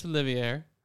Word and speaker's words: Olivier: Please know how to say Laurence Olivier's name. – Sir Laurence Olivier. – Olivier. – Whoa, Olivier. – Olivier. – That's not how it's spelled. – Yeah, the R is silent. – You should, okay, you went. – It's French.